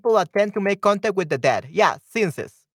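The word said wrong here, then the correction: séance: sense, seance. Okay, seance. That's the pronunciation.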